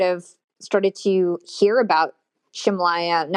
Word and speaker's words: Ximalaya: So I was mentioning an app called Shimalaya, and that's spelled X-I-M-A-L-A-Y-A, but it's pronounced Shimalaya.